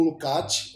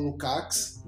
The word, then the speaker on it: Lukács: Marx tem muitas reflexões a respeito da ontologia, assim como Lukács, ou Lukács, depende da, da pronúncia que você é, mais gosta.